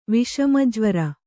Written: ವಿಷಮಜ್ವರ

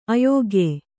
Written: ಅಯೋಗ್ಯ

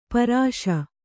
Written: ಪೌರುಷ

